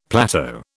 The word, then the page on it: plateau: British English